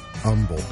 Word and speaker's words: humble: I wouldn't say I had to eat humble pie. – I've never heard humble. – Yeah, there's some people that just don't pronounce the H in humble, like herb and herb. It's herb and humble.